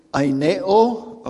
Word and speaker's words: aineo: And that is the word aineo, okay? It'd be an A-I-N-E, long O.